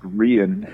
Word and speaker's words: Rian: I wanted to delve into the character, and just for the sake of argument, Lawrence, her name is pronounced Rian, and I chose that name. It's Irish, and it is used as both a boy's and a girl's name, and that's going to become very germane as the narrative progresses.